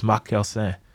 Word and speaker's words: McKersin: So in the French Bible, Mark is abbreviated with MC because it's spelled with a C, M-A-R-C. So that's how I got my name, McKersin, which in French and Creole is McKersin.